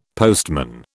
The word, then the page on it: postman: British English